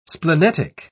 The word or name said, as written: splenetic